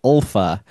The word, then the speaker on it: Ylfa: Toni Collette in this movie as well played Ulfa, which Y-L-F-A is how that character's name is spelled. Ulfa, the wife of Mark Ruffalo's character.